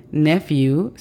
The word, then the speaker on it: nephew: La pronunciación fonética de esta es N-I-S. Niece. Por lo contrario, tenemos nephew. Nephew significa sobrino. La pronunciación fonética de esta palabra es N-E-F-I-U. Nephew. Nephew es una de las palabras que contiene las letras PH juntas.